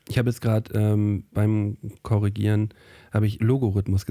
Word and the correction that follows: Logarithmus: gesagt. Das heißt natürlich Logarithmus.